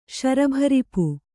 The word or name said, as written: ಶರಭರಿಪು